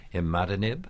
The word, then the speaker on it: imatinib: That's the brand name, although isn't it also called imatinib? – Yes, that's correct. – Imatinib sounds like a word that's backwards.